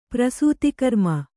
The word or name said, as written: ಪ್ರಸೂತಿಕರ್ಮ